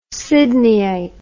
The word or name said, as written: sydneyite